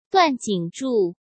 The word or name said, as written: Duan Jingzhu